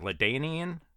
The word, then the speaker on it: LaDainian: Is it LaDainian? – Yep. – For Pittsburgh? – No. – Oh, dang it. – I thought you were talking about LaDainian Tomlinson, like going way back.